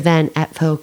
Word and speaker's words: Van Apfel: Listening to Felicity McLean discuss her amazing new book, The Van Apfel Girls Have Gone. Now, what, did I say that right? Yes, I got, yes. – That's right. Yes.